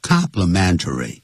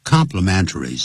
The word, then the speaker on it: complimentary: I easily spotted 当动词代表认出啊 I easily spotted Jenny and the crowd because of her red hat 人群中我轻易的从那顶红帽就看到了这个 Jenny 好接着我们看第五 complimentary 也就是免费的但另外一个跟它的发音是一模一样的 complimentary 是 C-O-M-P-L-E 刚才是 C-O-M-P-L-I